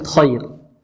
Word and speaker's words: خير: المفتوحة في النطق هناك فرق في النطق بارك الله فيكم خير لك خير الراء ماذا؟ الراء مفخمة خير